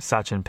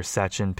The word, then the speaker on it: Satchin: So if you want to learn more about that, look up like rat study fasting, Dr. Sachin Panda. His name is spelled Dr. Dr. obviously, and then S-A-T-C-H-I-N, so like sat down and then chin, and then panda, spelled like panda, P-N-D-A, panda.